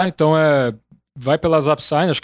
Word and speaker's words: Zapsign: É, vai, é Zapsign, tem gente que fala Zapsing, tamo topando tudo.